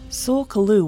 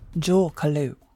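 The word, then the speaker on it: Tsul'kalu: Sul Kalu was the common pronunciation that I found during my research, but it may also be pronounced Jul Kalu.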